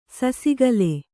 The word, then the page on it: ಸಸಿಗಲ್: sasigal